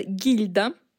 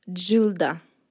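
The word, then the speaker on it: Gilda: Жилда, а в русском переводе это гильда, это фильм «Нуар» 1946 года, и на самом деле ее имя читается как Джилда. То есть это и не Жилда, но и не Гильда.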